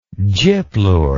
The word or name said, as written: gyplure